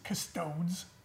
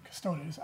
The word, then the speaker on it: Custodes: I think an Adeptus Custodes release, Custodes. – It's not a silent E. Custodes. I don't know. Because in Latin, it's phonetic. You pronounce every letter, if I'm correct.